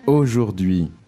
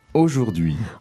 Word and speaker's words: aujourd'hui: Aujourd'hui. – Today. – That's it. – Let's focus on the pronunciation of that. Can you say it? – It's not that difficult. Aujourd'hui.